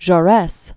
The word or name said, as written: Jean Jaures